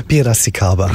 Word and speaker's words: Piracicaba: Du arbeitest für das traditionsreiche brasilianische Sinfonieorchester und jetzt kommt's, ich kann es wahrscheinlich nicht gut aussprechen, Piracicaba? – Ja, fast 90%. Ja. Piracicaba. – Piracicaba, okay.